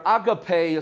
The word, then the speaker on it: agape: And it's where we get the word agape, or agape as some people pronounce it, agape love.